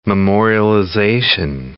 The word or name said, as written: memorialization